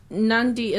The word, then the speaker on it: Nadi: So because you said Nandi, so Nandi is like with an N before the D. – Yeah, yeah. Oh, that's how you pronounce it, but it's spelled like it's spelled like N A D I.